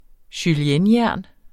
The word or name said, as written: juliennejern